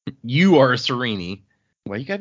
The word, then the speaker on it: Sarene: See, this one is deceptive. It looks easy, but it is another one of those with the hard consonants. So it is actually Serene is the correct pronunciation. – Serene. – Yeah. – I was gonna maybe go with Sarene. Serene. Well, that sounds like an insult. Like that. Well, check her out. She's a real Serene.